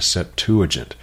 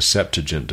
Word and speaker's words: Septuagint: However, there is some help that we can get from what is called the Septuagint, or the Septuagint, depending upon how you like to pronounce that.